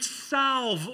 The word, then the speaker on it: salve: And then she would put salve over the wound. Salve is the root word for salvation. We pronounce it in the salvation, the L, but we don't pronounce the L in salve.